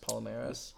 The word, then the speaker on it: Palmeiras: Palmeiras.